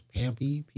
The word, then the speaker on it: PMPY: The project is this Promethean Prodigy business, which allegedly people pronounce as Pampy, P-M-P-Y.